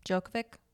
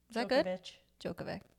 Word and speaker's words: Djokovic: Djokovic? Djokovic. I think that was pretty good. – Vich? – Yeah. Djokovic. – Novak Djokovic.